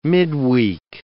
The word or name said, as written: midweek